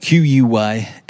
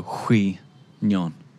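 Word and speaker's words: Quy Nhon: Q-U-Y-N-H-O-N. I want to say Quy-Nhon, but I know it's not. And I know that the Q is like a so Hui Nhon, potentially, something like that.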